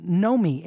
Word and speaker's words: Nomi: Lael, what'd you think of You Don't Know Me, N-O-M-I? – Well, you said it right.